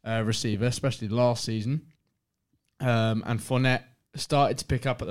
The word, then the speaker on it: Fournette: Leonard Fournette.